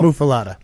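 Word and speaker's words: muffuletta: Is it muffalato? – Muffalata. – Muffalata. Yeah. Okay, throw an O on there. Muffalata and the jardinera.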